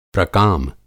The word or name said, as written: प्रकाम